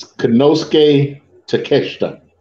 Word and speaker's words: Konosuke Takeshita: It's Konosuke Takeshita. – That's what I just said. – No, you over there talking about take a shit or whatever you said.